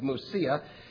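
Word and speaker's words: Mysia: "And after they came to Musia," and usually when you read English and you see in the New Testament a Y, it's usually a U sound in Greek. That's why I pronounce it Musia.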